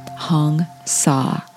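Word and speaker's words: Hong Sau: It's H-O-N-G, just like Hong, as in Hong Kong, and Sa, just like the word saw, S-A-W. It's actually spelled S-A-U in this case, but it's pronounced like the word saw, S-A-W, Hung Sa.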